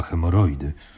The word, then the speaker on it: hemoroidy: No i wiem, że masz te hemorody, nie? – Chyba hemoroidy. – No właśnie, czyli jest coś na rzeczy, skoro wiesz, jak to się wymawia, nie? – Tak, Józef Siekierczak wstydził się swojej choroby.